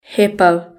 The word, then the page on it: hippo: British English